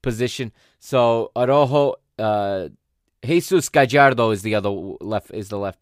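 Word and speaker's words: Araújo: In the back, he's got a center back pairing of Nestor Araujo, I believe is how they pronounce it. I want to say Araujo, but that's because I'm Portuguese. It's Araujo is how they pronounce it in Mexico.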